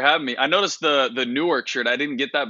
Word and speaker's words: Newark: I'm born and raised in Newark, and this is how it's supposed to be pronounced, N-O-R-K.